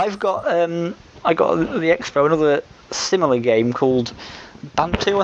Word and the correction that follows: Bandu: Bantu or Bandu? One or the other. Bandu, I think. I always get the name wrong. Look at that. Yes, Bandu, which came out, which is kind of similar.